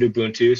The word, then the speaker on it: Ubuntu: Yeah, and I pronounce it Ubuntu, so okay. The people you talk to, you might hear something different.